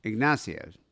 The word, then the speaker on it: Ignacio: Ignacio.